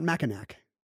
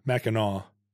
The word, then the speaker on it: Mackinac: They also have a silent C. It's not Mackinac. – Right. Mackinac.